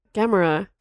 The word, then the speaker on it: Gamora: Gamora. – Gomorrah. – Gomorrah? – It's Gomorrah. – It's spelled differently, though. – Well, isn't it? – We're pronouncing it the same way. The green chick. – Yeah, it's Gomorrah. – Aha. – G-A-M-O-R-A.